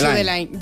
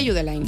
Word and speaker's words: Judeline: No es Judeline, Judeline. Si es de Barbate, Judeline.